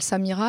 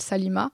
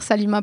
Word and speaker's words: Salima: Voilà, je parle de ce que j'ai pu subir quand je travaillais à la banque et Et à chaque fois que ma chef d'agence m'appelle Samira, alors que je m'appelle Salima, alors qu'il y a trois syllabes, hein, c'est compliqué à retenir Salima pour elle.